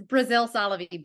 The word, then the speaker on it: Braziel-Solovy: Did I say your last names right? – Oh, it's Braziel-Solovy, but literally nobody says it right.